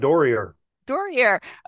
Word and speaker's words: Doerrier: Doerrier.